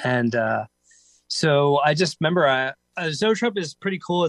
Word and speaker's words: zoetrope: So it's got what's called, I don't know how to pronounce it, Zoetrope? Is that, or Zoetrope? – Zoetrope.